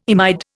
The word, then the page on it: imide: Indian